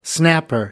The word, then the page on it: snapper: American English